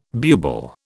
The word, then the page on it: bubal: British English